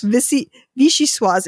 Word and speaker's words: Vichyssoise: But it's Vichy, like the region in France. – Ah, yes. – Vichy Soise. It's spelled V-I-C-H-Y-S-S-O-I-S-E.